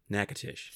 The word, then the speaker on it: Natchitoches: Natchitoches. – Thank you. Never going to get it. – Nope. – Natchitoches. It needs to be written down in front of me. Natchitoches.